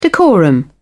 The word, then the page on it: decorum: British English